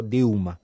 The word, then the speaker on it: Dilma: como se pronuncia en portugués, ¿no? Porque la L intermedia o al final de sílaba, la L es U, de ahí que es Brasil, ¿no? O Dilma, o Gau Costa, ¿no?